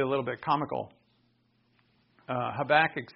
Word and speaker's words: Habakkuk: Because for half the time I say Habakkuk, and the other half I say Habakkuk. Turns out it's Habakkuk.